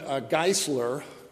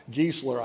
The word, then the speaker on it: Geisler: So, Norman Geisler, pronounced, if you pronounce his name in German, E-I, it's be a long I. Geisler, I guess, would be maybe the English.